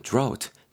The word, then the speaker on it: drought: 여러분, drought. D-R-O-U-G-H-T가 되는데요. 발음 drought 정도로 하시면 될 것 같고, drought 하지 마시고 drought 정도로 하시고요.